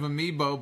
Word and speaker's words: Amiibo: You're not a fan of Amiibo, but you pronounce it correctly.